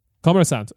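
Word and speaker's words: Kommersant (Коммерсантъ): How do you pronounce? Kommersatni. – Kommersant. – Okay, there we go.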